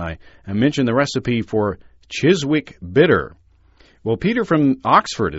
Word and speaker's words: Chiswick: it would always be pronounced Chiswick," the W being quite silent.